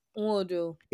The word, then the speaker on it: Nwodo: Nwodo. – Nwodo. Okay. Thank you so much. I'll just skip to actually pronounce it like the N is in there.